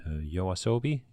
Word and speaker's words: Yoasobi: En ook nog eens een opening door uh, Yoasobi. Zeg ik dat goed? – Ja.